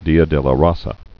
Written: Dia de la Raza

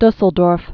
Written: Dusseldorf

